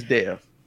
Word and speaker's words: Dev: Because that does sound like Dave. But Dev Patel, Dave Patel. There you go. It's Dev. In this movie, Dev got ops. There you go. That's how I'm going to remember how to say his name.